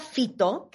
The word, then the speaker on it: Phyto: De la marca Fito, que se escribe P-H-Y-T-O, eh, ama el champú morado.